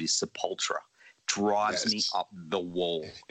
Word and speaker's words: Sepultura: But we make fun of it, you know, ourselves. We'll be like, Sepultura, hard, heavy. I love that man, you know, but I mean, those guys don't care. I mean, I don't care. So, but it's funny, but it's Sepultura.